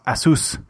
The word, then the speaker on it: Asus: Han påpekar mycket riktigt att det uttalas Asus. Mm. För det är då Pegasus, fast utan P och G. Det är det namnet kommer ifrån, så det ska sägas Asus.